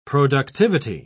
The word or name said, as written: productivity